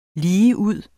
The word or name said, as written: ligeud